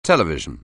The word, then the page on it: television: anglais